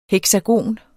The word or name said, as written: heksagon